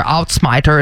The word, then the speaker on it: Uitsmijter: Outsmiter is how you say it, though.